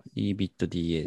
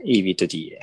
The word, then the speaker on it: EBITDA: いくつか指標を紹介させていただいてるんですけど、まず、えー、これは EBITDA って読むんですかね。合ってますかね。はい。 EBITDA ですね。 EBITDA。はい。これが、えー、過去最高値まで成長していたと。で、この EBITDA 何かっていうと、